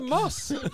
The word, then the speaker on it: muss: What the fuck's a muss? – You should know what a muss is. Everyone knows what a muss is. – You know what it is? – The muss? – Rhymes with bus.